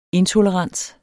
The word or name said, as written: intolerance